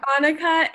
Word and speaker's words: Anneke: We have Annika. Did I say that right? Yes, we have Annika, and I found her on TikTok. Her TikTok username is A-N-N-E-K-E.